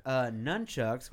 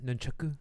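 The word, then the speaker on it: nunchaku: He's also got nunchucks, which have three sticks. – I think it's pronounced nunchuku.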